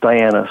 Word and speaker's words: Diana: Actually, it's always Diana, so I was just referencing the I've been reprieved.